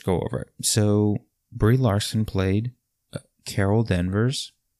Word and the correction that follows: Danvers: No, I'm sorry, Carol Danvers. Carol Danvers, that's how you pronounce it, or Captain Marvel.